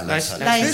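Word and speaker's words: Laeiszhalle: Wie spricht man das aus? Leis. Da am Brahmsplatz. – In diesem wunderschönen. Wie heißt denn die Leishalle, ne, oder? Ja, Leishalle. – Wird bloß so komisch geschrieben.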